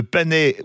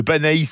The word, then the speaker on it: panais: Apéritif gascon offert, entrée escalope de foie gras du Gers, sa vinaigrette au petit condiment, mousseline de panais ou de panaïs selon comment on prononce aux noisettes et crème de volaille du Gers au citron.